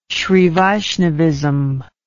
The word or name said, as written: srivaishnavism